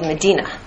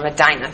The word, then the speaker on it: Medina: Yatrib is now called Medina, or Medina, depending on how you pronounce it.